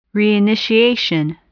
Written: reinitiation

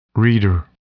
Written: reader

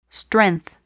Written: strength

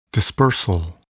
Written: dispersal

